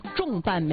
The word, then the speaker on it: 重瓣: 那不应该重瓣吗？多重瓣吗？ – 啊，但不是，就是它的标准读音读重，重瓣玫瑰。